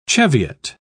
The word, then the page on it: cheviot: British English